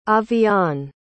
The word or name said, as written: avyaan